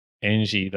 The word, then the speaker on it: Angie: I think it might be pronounced as like Engie, the power company.